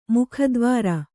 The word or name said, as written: ಮೇಘದ್ವಾರ